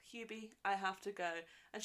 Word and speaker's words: Hubie: She's like, you can't even say his name right. It's Hubie, and he goes, I'm sorry. I was Peeby.